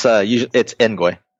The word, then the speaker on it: Engoy: Yeah, it's Engoy.